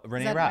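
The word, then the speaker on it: Reneé Rapp: Oh, Renee Rapp.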